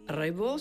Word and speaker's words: Raybaud: Raibot se escribe R-A-Y-B-A-U-D.